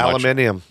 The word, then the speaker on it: aluminium: Aluminium. – Yes, that's how they say it in the UK.